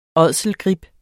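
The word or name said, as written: ÅDSELGRIB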